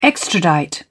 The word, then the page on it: extradite: British English